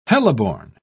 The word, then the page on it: heliborne: British English